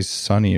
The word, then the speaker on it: SUNY: And she's written peer-reviewed studies on hair thinning, hair loss treatments, botanical medicine, and has an MD and a dermatology fellowship from, how do you say it, S-U-N-Y, or did you say Sunny if you're cool? – No, it's SUNY.